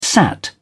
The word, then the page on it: sat: British English